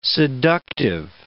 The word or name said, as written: seductive